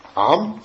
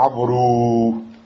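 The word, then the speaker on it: Amr (عمرو): Seulement, ce waou, on ne le prononce pas. Tu dis pas Amru.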